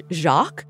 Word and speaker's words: Jacques: And they pronounced it like the French Jacques.